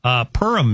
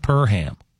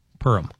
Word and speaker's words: Perham: Perham, Minnesota. It's not Perham. Yeah, I would imagine that would be a quick correct in that town. It's Perham. Purim.